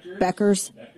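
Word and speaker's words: Bueckers: Beckers.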